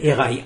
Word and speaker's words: Herai: Erai, H-E-R-A-I.